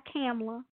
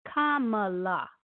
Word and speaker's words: Kamala: And let me tell you, pronounce her name correctly. It's not Kamala or anything else. It's Kamala. Kamala Harris. Get it right.